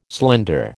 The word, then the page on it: slender: American English